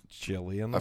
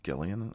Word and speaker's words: Jillian: Is it Jillian or Gillian? Because I've seen... – Jillian. – I've heard people pronounce it Gillian. I'm like, that doesn't sound right.